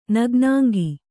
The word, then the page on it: ನಗ್ನಾಂಗ: kannada